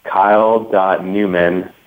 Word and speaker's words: Neumann: Yeah, likewise, they can email me at kyle.newman, and my last name is spelled N-E-U-M-A-N-N.